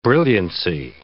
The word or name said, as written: brilliancy